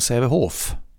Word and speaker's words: Sävehof: Han pratar ju ofta om Sävehof. – Just det. – Med det uttalet också. – F-et på slutet.